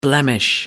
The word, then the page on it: blemish: American English